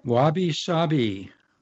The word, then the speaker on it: wabi-sabi: which is it wabi-sabi? Is that how you pronounce it? – Wabi-sabi.